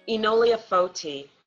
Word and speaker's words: Enolia Foti: Enolia Foti. – Foti. Okay. I think I would have said Fauti. So thank you for the pronunciation.